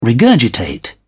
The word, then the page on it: regurgitate: British English